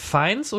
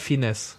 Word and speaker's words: Fiennes: Äh, Ralph Fiennes oder Ralph Fiennes? Ralph Fiennes, Fiennes, oder? Fiennes? Fiennes. Nein, Fiennes. Fiennes. Ich hätte Fiennes gesagt. Ja, Fiennes.